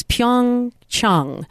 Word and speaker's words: Pyeongchang: In fact, let's talk about that for just a second, because a Korean friend of mine said it's Pyeongchang. And yet we're going to be hearing a slightly different pronunciation with everyone who's a part of NBC.